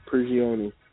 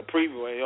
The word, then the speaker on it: Prigioni: Uh, the Rockets traded uh, Alex Alexey Shved for, for uh, to the Knicks for Pablo Brignani. Uh, let's see, the Celtics Prigioni. traded eight. – Did I, did I pronounce that right? – No, Prigioni. – Oh, Prigioni. Eh, okay.